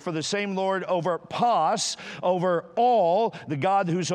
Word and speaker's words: pas: All four of those words, whosoever and all, are the Greek word pas. P-A-S would be the phonetic pronunciation of that, pas.